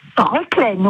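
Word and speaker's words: Reclesne: On prononce Reclaine ? – Reclaine, oui. On ne prononce pas le S.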